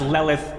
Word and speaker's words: Lilith: Oh, the Lilith. Oh, there are many Liliths. I didn't know you were the genuine article. – It's actually pronounced Lelith, but go ahead.